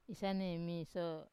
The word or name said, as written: isane miso